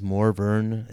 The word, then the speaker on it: Morvern: Morvern Collar, and I know it is Morvern. It's spelled weird.